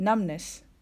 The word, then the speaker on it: numbness: B がサイレントになっていますなので発音する時にはナムブネスというように B を入れないように気をつけましょうナムネスナムネスと発音します